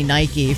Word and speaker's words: Nike: Is that how it's pronounced? Nike? – I don't know. I'm going to say it. – I assumed it was Nike because that's how we say it. That's how it is pronounced.